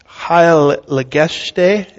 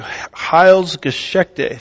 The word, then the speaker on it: Heilsgeschichte: Heilgeschichte? Is that how you say that? What is it? Thank you. Heilsgeschichte. Thank you so much.